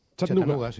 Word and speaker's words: Chattanooga: ¿Se pronuncia así en Chattanooga? – Chattanooga. City. – Sí.